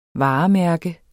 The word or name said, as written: varemærke